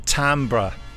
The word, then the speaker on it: timbre: And I think timbre is how you pronounce the familiar voices thing.